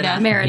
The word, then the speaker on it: Merida: Merida.